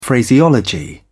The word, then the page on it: phraseology: British English